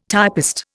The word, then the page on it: typist: Indian